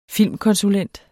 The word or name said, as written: filmkonsulent